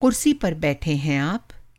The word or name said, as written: कुर्सी